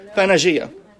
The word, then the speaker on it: Pangaea: How do you pronounce Pangea or Pangaea? Pangaea.